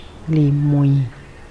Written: limuin

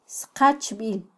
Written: sqachbil

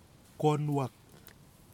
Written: konuwak

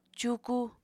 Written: cuuku

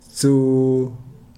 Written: tsuu

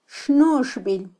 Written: xnuxbil

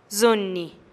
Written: zunih

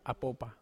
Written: apopa

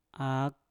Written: ak

